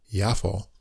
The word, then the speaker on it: Jaffa: That's J A F F A, but I guess it's pronounced Yafo.